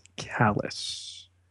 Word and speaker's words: Kallus: Really the only one who gets a name in the premiere movie here is Imperial Security Bureau Agent Kallus. – And it's spelled K-A-L-L-U-S, even though yes. It sounds like the word that means uncaring.